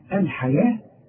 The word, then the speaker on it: الحياة: اشتروا الحياة الحياة مكتوبة بالواو ولكن تنطق بالالف ورسم لك الف فوق الواو بيقول لك تنطقها الف كامله